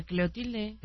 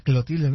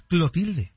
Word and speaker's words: Clotilde: Doña Cleotilde. – Era Clotilde, ¿no? Acá es Clotilde. – Ah, pues tal vez era Clotilde y nosotros lo pronunciábamos mal.